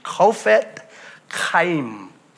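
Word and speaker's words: Chofetz Chaim: There's a lot of different pronunciations for his name, but I believe the correct pronunciation of his name is Kofet Chaim.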